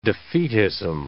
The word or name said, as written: defeatism